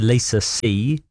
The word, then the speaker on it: See: It's by Lisa C. C is spelled S-E-E.